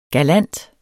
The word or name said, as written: galant